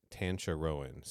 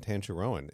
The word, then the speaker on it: Tancharoen: And in an interview, he's introduced as Kevin Tancharoen. So that's the pronunciation I tried to go with throughout the episode.